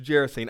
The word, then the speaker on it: Gerasene: This G is really soft like a J, so it's Gerasene.